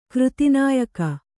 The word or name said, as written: ಕೃತಿನಾಯಕ